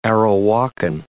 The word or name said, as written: Arawakan